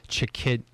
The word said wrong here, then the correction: Chikezie: Chikezie. So his KZ is obviously just KZ is good enough, right? Yeah. Especially for guys like me. – Chikezie.